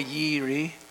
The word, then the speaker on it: Jireh: So the first one you've heard of it is Jehovah Jireh, or it may be pronounced Jehovah Yireh.